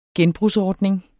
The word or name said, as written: genbrugsordning